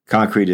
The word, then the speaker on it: Concreit: Concrete is spelled C-O-N-C-R-E-I-T, so yep, the spelling of the company's name is right.